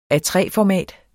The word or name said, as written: A3-format